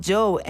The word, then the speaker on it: Djo: Como actor le conoces por su participación en Stranger Things, como músico, también como Joe. En realidad se escribe D-J-O, por si lo vas a buscar.